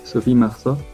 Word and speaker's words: Sophie Marceau: Sophie Marceau.